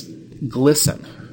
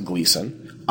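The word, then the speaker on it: Glisan: And it's Glisson. and it's the G Street in the Alphabet District. Everybody calls it Gleason. I even sometimes call it Gleason, because if you say Glisten, people don't even know what you're talking about right.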